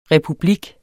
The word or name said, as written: republik